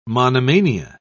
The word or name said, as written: monomania